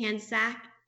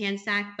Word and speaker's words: Hancsicsak: And again, Hancsicsak, I'm going to spell it now, guys, because it's a long name. H A N C S I C S A K.